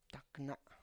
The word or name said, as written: takna